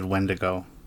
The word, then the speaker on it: Wendigo: Every phonetic spelling online that I could find said Wendigo. And that's how I had always heard it.